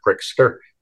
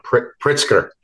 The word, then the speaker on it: Pritzker: Yeah, it was right at the peak of forced masking in Illinois with Governor Pritzker. Sorry, I said that wrong. Pritzker.